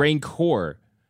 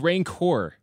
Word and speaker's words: rancor: Rancor. – Yeah, say it right. – I was so confused what you're talking. It's rancor. – R-A-N-C-O-R, rancor.